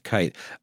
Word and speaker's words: Keith: In 1933, when the Nazis assumed power, a man by the name of Max Keitt, spelled like Keith but pronounced Kite,